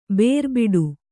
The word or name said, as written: ಬೇರುಬಿಡು